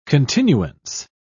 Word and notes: continuance: British English

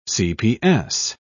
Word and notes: cps: British English

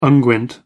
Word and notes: unguent: American English

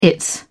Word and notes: its: British English

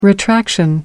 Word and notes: retraction: American English

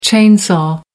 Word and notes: chain saw: British English

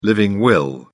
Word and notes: living will: British English

